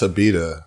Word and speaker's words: Abita: No, that's how you pronounce Abita. I know it looks like Abita, but you know how we do down here in Cajun funk.